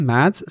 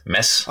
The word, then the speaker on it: Mads: So Mads is completely wrong. – Mads. – It's mess. – Oh, really? – Mess. – Wow. – Yep. – Like if you'd done a mess.